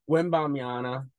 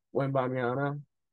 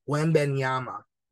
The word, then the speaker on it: Wembanyama: Wembanyama. – No, you're saying the N instead of an M at the end. Wembanyama.